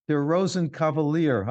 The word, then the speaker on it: Der Rosenkavalier: This is Suite from Der Rosenkavalier. I don't know if I pronounced that correctly. It's a Richard Strauss piece by the Cleveland Orchestra. – Perfect pronunciation.